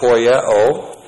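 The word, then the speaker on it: Poieo (ποιέω): And then, with the reference to Jesus Christ, who is our perfect example, we have the verb poieo. P-O-I-E long O. Poieo.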